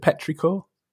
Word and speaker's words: petrichor: Petrichor? – Yeah.